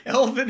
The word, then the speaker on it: Elvin: Evan but, Hayes, it is. Evan Hayes. Bro. Evan Hayes. This man Evan already Hayes, has been butchered. you didn't make it. – What? Elvin Hayes.